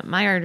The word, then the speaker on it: Maillard: And we are now on the Maillard reaction, which is everyone's favorite part, right? – Of course. I love it that it's two silent L's right in the middle.